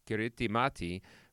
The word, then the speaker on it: Kiritimati: This Christmas Island that you were on is also known as Kiritimati, which is how you say Christmas in the language spoken there, Gilbertese.